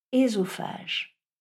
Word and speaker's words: œsophage: Mais attention, cette première syllabe, qu'elle s'écrive E accent aigu ou E dans l'eau, doit se prononcer avec un E fermé. Il faut donc dire Œdipe, œcuménique ou œsophage.